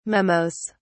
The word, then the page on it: memos: British English